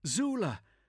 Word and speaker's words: Tzula: It's Zula, T-Z-U-L-A. The T is silent.